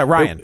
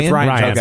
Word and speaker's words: Rian: Yeah, Ryan. – Ryan.